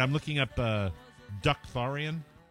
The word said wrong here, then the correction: Dothraki: It's Dothraki, I believe.